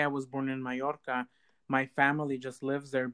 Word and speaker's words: Mallorca: Is that how you pronounce it? – Mallorca.